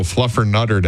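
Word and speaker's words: Fluffernutter: Happy National Flutter Mutter Day. – Excuse you? – Fluffer Mutter Day. – Nope. Try again. – Not for you? – You're still saying it wrong. – Oh, Fluffer Nutter Day.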